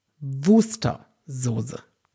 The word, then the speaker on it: Worcester-Soße: Aber kaum ein Wort hat so viel Aussprachen wie Worcester-Soße oder Worcester-Soße oder Worcester-Soße. Ich habe das mal aufgebröselt und ich glaube, kaum einer sagt es wirklich richtig. Worcester. Und es heißt, Worcester-Soße. Wirklich. Man spricht es Worcester-Soße.